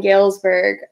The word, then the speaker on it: Galesburg: Galesburg, Michigan, where you grew up? – Yeah.